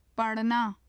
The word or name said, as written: parna